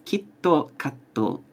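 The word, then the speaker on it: Kitto Katto (キットカット): Kit Kat in Japanese is pronounced Kitto Katto, and Kitto means certainly, without fail.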